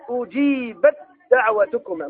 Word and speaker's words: أجيبت دعوتكما: ثانيا التاء في الدال، الآن نقلب التاء في الدال في نحو قوله تعالى أجيبت دعوتكما، ما تنطق التاء أجيبت دعوتكما، طيب من الباء إلى الدال أجيبت دعوتكما، حتى تلاحظ هنا فوق الدال شدة والتاء ليس فوقها شيء.